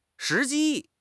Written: 时机

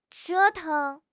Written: 折腾

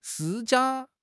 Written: 施加

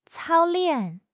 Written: 操练

